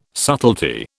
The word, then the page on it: subtlety: British English